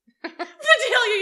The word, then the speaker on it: Vidalia: Vidalia onions. – Vidality. Vidalia, you said it right.